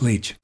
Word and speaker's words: Leitch: Leech or Licht. – That's what it is, Leech. That's the leech. Answer. – So if I had said it correctly, you would have gotten it right leech. – Away. Yeah, Leech. And the spelling of Leech is L-E-I-T-C-H.